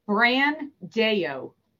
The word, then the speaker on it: Brandao: Brandao. – Brandao. All right. Just like it's spelled.